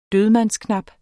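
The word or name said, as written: dødmandsknap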